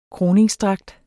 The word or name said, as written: krone